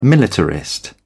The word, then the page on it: militarist: British English